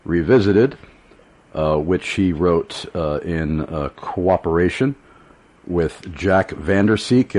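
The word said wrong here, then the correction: Vander Slik: Am I pronouncing Jack's? Slick, I'm sorry. Jack Vander Slick. I'm sorry? – Yeah, Slick is right.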